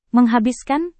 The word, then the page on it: menghabiskan: Indonesian